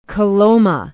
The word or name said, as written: coloma